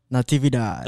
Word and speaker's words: Natividad: Natividad.